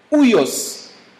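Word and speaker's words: ouios (υἱός): Le mot fils ici, utilisé par Paul, est un mot grec que l'on prononce ouios. Ouios.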